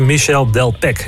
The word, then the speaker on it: Michel Delpech: Ja, dat is net zoiets als Michel Delpech. Dan spreek je de H weer niet uit.